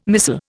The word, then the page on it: missal: Indian